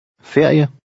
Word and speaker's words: ferie: Here, each letter is pronounced. – Ferie.